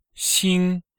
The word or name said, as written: xing1